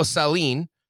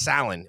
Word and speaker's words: Salin: So Otto Sahlin. By the way, DB, that is the correct pronunciation. I did confirm that, but they were getting it wrong on the TV broadcast, referring to him as Salin, at least early on.